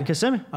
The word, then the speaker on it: Kissimmee: Well, Kissimmee. Did I say that correctly? – Yeah, Kissimmee.